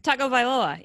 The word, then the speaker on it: Tagovailoa: Tagovailoa.